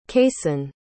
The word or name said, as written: cayson